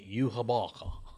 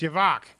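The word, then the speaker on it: Yhwach: That's not how. – It's Yavok. Okay, Yavok.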